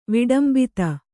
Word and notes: ವಿಡಂಬಿತ: viḍambita

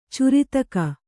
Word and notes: ಚುರಿತಕ: Kannada

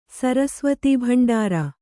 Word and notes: ಸರಸ್ವತೀಭಂಡಾರ: sarasvatī bhanḍāra

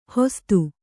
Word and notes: ಹೊಸ್ತು: hostu

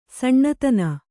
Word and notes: ಸನಾತನ: sanātana

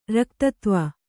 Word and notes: ರಕ್ತತ್ವ: Kannada

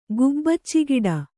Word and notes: ಗುಬ್ಬಚ್ಚಿಗಿಡ: gubbacci giḍa

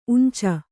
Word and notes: ಉಂಚ: uñca

